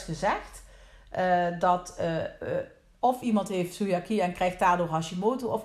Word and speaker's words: celiakie: Je hebt dat bij celiakie, dat is een intolerantie of allergie tegen gluten. Zeg ik dat goed? – Celiakie? – Ja, celiakie. Daar is het bekend dat het de schildklier daar heel nauw mee verbonden is.